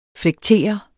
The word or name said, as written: FLEKTER